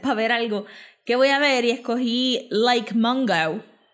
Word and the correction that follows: Lake Mungo: O Lake Mungo.